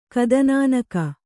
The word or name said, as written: ಕದನಾನಕ